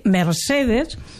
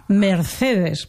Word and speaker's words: Mercedes: En comptes de dir Mercedes, com s'ha dit tota la vida, ara diuen Mercedes, per posar un exemple que va en la mateixa línia del que tu dius, no?